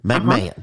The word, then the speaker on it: McMahon: But some of these politicians like Chad McMahon or how do you pronounce his name? Is it McMahon? – McMahon.